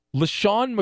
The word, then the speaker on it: LeSean: His given name is L-E-S-H-A-W-N. He goes by L-E-S-E-A-N. How odd is that? Like, his name it's pronounced the same, but he spells it differently than his birth name.